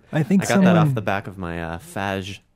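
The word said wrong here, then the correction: Fage: Faya. Is the way to pronounce that.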